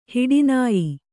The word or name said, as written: ಹೊಟ್ಟೆನೋಯು